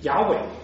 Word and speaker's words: 雅伟: 耶和华呢，他原来的名字啊，在希伯来文里面发音就是雅伟。